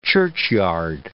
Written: churchyard

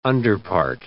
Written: underpart

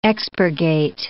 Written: expurgate

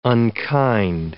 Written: unkind

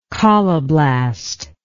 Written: colloblast

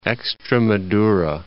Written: Extremadura

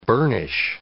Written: burnish

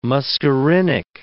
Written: muscarinic

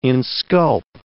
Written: insculp

